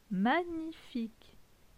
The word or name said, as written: Magnifique